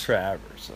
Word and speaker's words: Travers: My grandmother pronounced it Travers. – Travers, okay. – So, my father was a traveling salesman that hated the way his name was pronounced. – Yeah. – So we changed it. – Yeah. – And I continue to change it. – Oh, yeah? So where are we at now with it? – Travers. – Travers? – Yeah, with a long A.